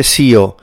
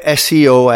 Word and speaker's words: SEO: E quindi quando stiamo parlando di esperti SEO o professionisti SEO, in inglese non si dice CEO, perché CEO è quell'altra cosa, ma si dice SEO expert.